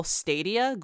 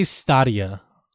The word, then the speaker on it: Stadia: But one of the biggest announcements that just came out is Google Stadia. I don't even know how to pronounce it. – They say Stadia.